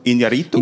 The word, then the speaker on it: Iñárritu: Iñárritu. – Iñárritu, because the accent is on the U.